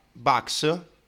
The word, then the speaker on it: Bucks: E, ehm, a proposito di BUCS, che si pronuncia Bucks, sì, anche perché Bucks si scrive in maniera diversa.